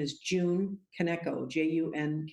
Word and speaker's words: Kaneko: That's, and I'm not sure how to pronounce Kaneko, is it? – It is. June Kaneko. – Kaneko, yes.